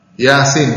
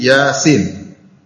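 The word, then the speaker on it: yasin (يس): Yasin bukan dibaca yas, tapi yasin.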